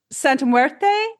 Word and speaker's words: Santa Muerte: Pop culture magic, Santa Muerte.